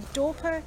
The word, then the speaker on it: Dorper: Today I went on a mission to try and find out more about Dorper sheep. And have I pronounced that right? – You have. – I have. So I'm here on a Dorper sheep farm in Toolgum.